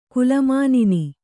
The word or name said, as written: ಕುಲಮಾನಿನಿ